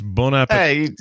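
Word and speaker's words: bon appétit: you absolutely don't pronounce the last t it's french it's bon appetit hey, Yeah.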